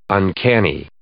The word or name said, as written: uncanny